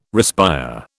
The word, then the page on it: respire: British English